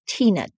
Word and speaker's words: Teena: And so my name was Tina, T-E-E-N-A, which was my mom thought a clever way to spell Tina.